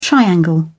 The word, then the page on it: triangle: British English